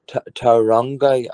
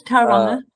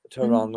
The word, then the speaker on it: Tauranga: We were in Wellington, of course, yesterday, and then we were in Tauranga. I'm saying that wrong. – No, not but, too bad. Tauranga. – Tauranga.